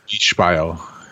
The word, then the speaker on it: Beispiel: Should be pronounced Beispiel. – I think I know how to spell that from German. Or do I? Yeah. – It's the German spelling with an American pronunciation.